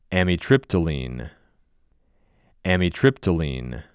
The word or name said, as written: Amitriptyline